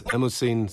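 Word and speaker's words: Mnemosyne: ja, dieses Stück, also äh, ja, das ist von der griechischen Mythologie inspiriert worden Und zwar ist Emosine, so dürfen wir es aussprechen, die griechische Göttin also von Gedächtnisvermögen.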